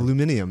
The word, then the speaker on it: aluminium: Or is it going to be the people that make the aluminum shells, the aluminum shells? – Where did you get that from? – Aluminium. That's how they pronounce it.